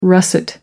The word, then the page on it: russet: American English